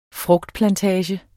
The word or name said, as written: frugtplantager